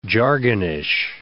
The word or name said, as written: jargonish